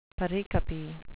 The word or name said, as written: pericope